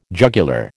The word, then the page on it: jugular: American English